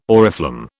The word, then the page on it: oriflamme: British English